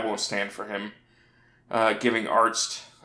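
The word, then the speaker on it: Arzt: Hurley and Arst kind of, you know, banter back and forth about his name because Hurley pronounces it Arnst instead of Arst.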